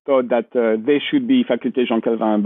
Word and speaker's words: Faculté Jean Calvin: Faculté Jean Calvin.